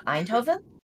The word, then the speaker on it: Eindhoven: Eindhoven.